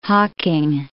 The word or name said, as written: Hocking